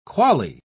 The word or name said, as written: quale